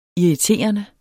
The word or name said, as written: irriterende